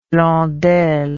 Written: Blondel

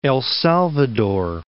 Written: El Salvador

